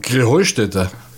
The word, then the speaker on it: Krähenheinstetten: Ja, ihr saget immer Krähenheinstetten. Und dabei heißt es doch Krähenheustätte. Oder wie ist es? Krähenheustätte. – Ja, genau. Und das die Betonung auf dem Heu.